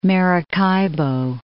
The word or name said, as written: Maracaibo